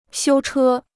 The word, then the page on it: 修车: xiū chē